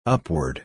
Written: upward

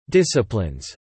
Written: disciplines

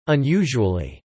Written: unusually

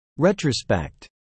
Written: retrospect

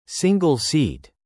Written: single-seat

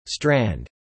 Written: strand

